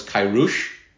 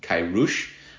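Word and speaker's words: Kourosh: The name Cyrus does not sound anything like the guy's actual name. His name was Kairush. Cyrus is a side effect of the fact that we pronounce the letter C as an S, but his name starts with a K.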